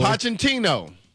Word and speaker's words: Pochettino: All right, you're going to help me out with the pronunciation of your guy's last name here. Mauricio Pochettino. – Pochettino? – Pochettino.